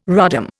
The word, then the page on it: rodham: Indian